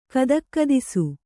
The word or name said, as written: ಗದಗದಿಸು